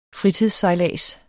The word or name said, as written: fritidssejlads